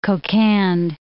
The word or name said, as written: Kokand